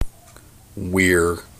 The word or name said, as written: Weir